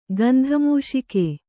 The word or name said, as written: ಗಂಧಮೂಷಿಕೆ